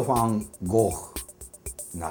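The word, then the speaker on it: Van Gogh: あえて近いのはドイツ語風に発音するとファン・ゴーフなるかなっていう感じなんですけどもまあそんなことですわオランダ語の G は覚えておいてくださいこれすごい多いです。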